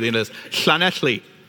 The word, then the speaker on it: Llanelli: And so the name of this town is not Llanelli, as many Americans or people from around the world would pronounce it. It's Llanelli. Llanelli.